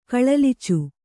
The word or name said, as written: ಕಳಲಿಚು